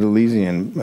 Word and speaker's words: Elysian: I mean, here they pronounce it Elysian in Los Angeles, but I'm sure I'm going to get emails that it's something else.